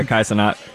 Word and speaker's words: Kai Cenat: Am I saying it right? I know you're more in tune. – Yeah, Kai Cenat.